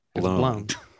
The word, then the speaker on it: Goldblum: Blum. – It's you, a you. It's a Jeff long, it's like a long you. – Jeff it's like Motley Crue.